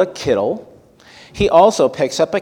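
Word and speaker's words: kittel (קיטל): That's spelled K-I-T-T-E-L, kittel.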